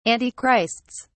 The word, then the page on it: antichrists: British English